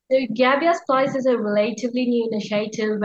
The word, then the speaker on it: Gabiasplice: Is what am I saying that right? Gabia Splice. – Yeah, that's perfect.